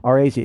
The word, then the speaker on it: Arazi: I believe I said that right. Arazi. It's A-R-A-Z-I for anybody who wants to look it up.